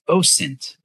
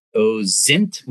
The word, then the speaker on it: OSINT: And in our discipline, it's pronounced OSINT with an S. It's not OZINT with a Z, and it's not AZINT.